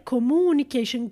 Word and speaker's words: communication: Careful with the pronunciation of this word, communication. Sometimes when I am in the middle of class teaching English courses, I hear students say, you know, communication, right? Communication, careful.